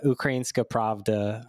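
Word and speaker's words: Ukrainska Pravda (Українська правда): So explain to me, to everyone who's listening, they probably don't know a ton about like Ukrainian media, but explain Ukrainska Pravda. I don't know if I'm pronouncing it correctly. So you're you're pronouncing it like quite professionally.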